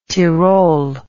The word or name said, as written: Tirol